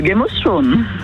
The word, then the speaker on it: Game of Thrones: Game of Thrones. – Oh, bien prononcé en plus, exactement.